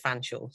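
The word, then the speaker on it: Featherstonehaugh: Fanshawe is spelt Featherstone Huff, but we pronounce it Fanshawe.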